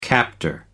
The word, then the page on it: captor: American English